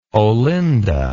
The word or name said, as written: Olinda